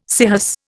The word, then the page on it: scirrhus: Indian